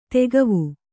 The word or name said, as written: ದಿಖಾವು